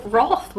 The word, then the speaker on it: Wrath: Do you pronounce it Wrath with an O? – Wrath, yep.